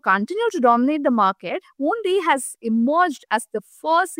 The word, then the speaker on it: Ownly: Rapido's food delivery platform Only, which is spelled O-W-N-L-Y, has captured around 7% of Bengaluru's food delivery market in just 4 to 5 months of its citywide rollout.